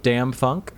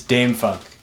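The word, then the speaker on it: DâM-FunK: So is it Dam Funk or Dom Funk? It's Dame Funk. – Dame, of course, the third option.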